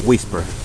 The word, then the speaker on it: WSPR: Aunque en inglés esta abreviatura se pronuncia como whisper, que significa susurro.